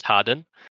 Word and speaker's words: Hardon: Yeah, my character is Harry Harden, spelt Hardon, pronounced Harden.